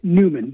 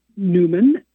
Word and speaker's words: Neumann: So it's written Neumann, N-E-U-M-A-N-N. – Right. – Neumann.